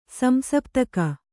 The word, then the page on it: ಸಂಶಪ್ತಕ: kannada